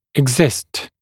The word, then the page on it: exist: ɪg’zɪst; иг’зист